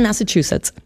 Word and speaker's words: Massachusetts: Du kommst aus Massachusetts. – Genau, Brockton, Massachusetts. – Habe ich es richtig ausgesprochen? – Ja. – Das ist auch ein schwerer Name, also für Deutsche ist das schwer zu sprechen, ne? – Das ist natürlich ein Native American Name.